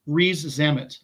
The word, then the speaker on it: Rees-Zammit: Rees-Zammit.